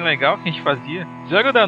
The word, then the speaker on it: Zelda: Como é que pronuncia isso, cara? Zelda. – Ah, Zelda? – Zelda, Zelda.